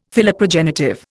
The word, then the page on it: Philoprogenitive: Indian